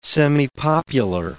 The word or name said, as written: semipopular